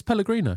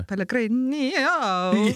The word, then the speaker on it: Pellegrino: I feel like there's an extra syllable in there. – No, it's Pellegrino. – Pellegrino. – Yeah, that's exactly right. – That's how you pronounce it.